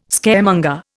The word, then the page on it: Scaremonger: Indian